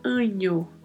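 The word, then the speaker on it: año: a letra ñ, que é esse n com o tio em cima, que tem o som do NH do português. Então nós falamos anho, anho, como se fosse escrito com o NH.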